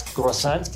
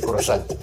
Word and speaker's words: croissant: Un día un amigo francés me dijo que lo pronuncié como si, como si un gringo dijera popocatépetl y que lo dijera mal. Así dije croissant, que es croissant, algo así, que tiene su estilo, ¿eh?